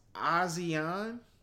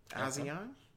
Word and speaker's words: Asiahn: I believe it's pronounced ASEAN. She's not a new artist. – That's a different language to me. I don't know. – I want to say it's spelled ASIAHN. ASEAN.